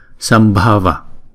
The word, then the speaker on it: Sambhava: Chữ B và chữ H chúng ta ghép lại, chúng ta đọc là B, B, B, Sambhava.